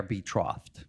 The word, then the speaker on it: betrothed: They are betrothed or engaged. – Okay, betrothed. Yeah. But it's already there, that concept marriage. – That's a better pronunciation, betrothed. Mine sounds like they're going to a trough.